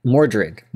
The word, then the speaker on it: Mordred: But like Mordor says, or what's his name? How do you pronounce it? – Mordor. Oh, yeah, that's right. That name is Mordred.